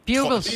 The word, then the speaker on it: Bugles: Især fordi, at der er så lige så mange måder at udtale det på, som der er knæse dem på. – Altså heksefingerchipsene. – Ja. – Ja, bugles.